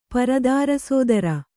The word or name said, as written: ಪರದಾರಸೋದರ